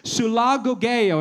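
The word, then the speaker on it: sulagogeo (συλαγωγέω): It's pronounced sulagogeo. Everybody say that. Say sulagogeo.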